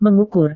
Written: mengukur

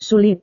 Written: sulit

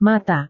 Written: mata

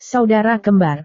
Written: saudara kembar

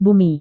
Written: bumi

